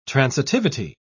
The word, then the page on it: transitivity: British English